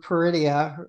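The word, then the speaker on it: Peridea: So let's go back to Peridia or Peridian, wherever Ahsoka and everyone is. – Yeah, I'm in my mind, it rhymes with perdition.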